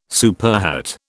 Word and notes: superhet: British English

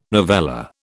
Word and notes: novella: British English

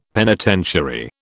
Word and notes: penitentiary: British English

